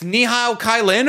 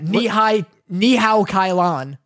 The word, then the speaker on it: Ni Hao Kai Lan: Ni Hao Kai Lan? What are we doing here? – Wow, you butchered that. Ni Hao Kai Lan.